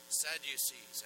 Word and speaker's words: Sadducees: They claim that they were descendants of Zadok, or Zadokese, Sadducees, as we pronounce it here.